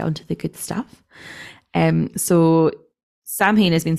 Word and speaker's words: Samhain: And a lot of Halloween traditions, especially ones we would know today, are influenced by Celtic harvest festivals, particularly the Gaelic festival Samhain. Am I saying that right? – Yes, I think you are, actually. Samhain, yeah.